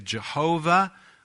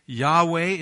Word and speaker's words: Yahweh: We would say Jehovah, which is probably a misunderstanding. Yahweh is more likely, because Hebrew did not use vowels.